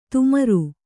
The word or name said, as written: ತುಮರು